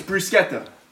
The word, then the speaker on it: bruschetta: It's bruschetta. C-H in Italian is K.